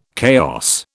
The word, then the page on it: chaos: British English